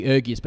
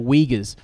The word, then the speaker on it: Uyghurs: Uh, in in China at the moment, I, it was a Four Corners report recently about the, I think I call them the Uyghurs, but they're actually the Uyghurs, I think is how you pronounce it, the Uyghur Muslims in the far northwest of China, in the Xinjiang province.